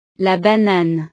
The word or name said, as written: la banane